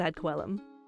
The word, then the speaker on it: ad coelum: And by the way, ad coelum, I have heard this pronounced different ways. I don't speak Latin, but we're going to go with ad coelum.